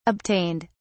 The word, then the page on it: obtained: British English